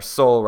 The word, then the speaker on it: Sol: Jord just means dirt or earth, and the sun is Solen in Swedish, or Sol, roughly pronounced Sol.